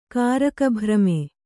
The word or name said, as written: ಕಾರಕಭ್ರಮೆ